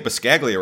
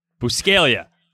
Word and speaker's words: Buscaglia: There's a silent G in there. Like, I get embarrassed about, like, oh, so you say it Biscaglia, right? No, actually, I have to correct everyone and say, no, the G is silent because I'm an idiot.